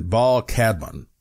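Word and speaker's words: Baal Kadmon: And his name is Baal Cadman, and that's spelled first name B-A-A-L, last name K-A-D-M-O-N, Baal Cadman.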